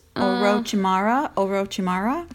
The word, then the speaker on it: Orochimaru: It is Orochimaru.